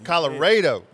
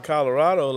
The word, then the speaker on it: Colorado: You have to say it, and, and that's how the locals know if you're from that area. Because if you call it Colorado like it's spelled, then they say, okay, this guy's not from West Texas.